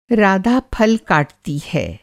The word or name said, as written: काटना